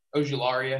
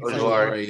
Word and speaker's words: Ojulari: I don't know if Zach will agree with, but um, Kwity Paye, Jaelan Phillips, um, Ajuari is that how you pronounce his last name? Ojulari, I think. – Ojulari, yeah. Hey, that was closer than I thought I would be.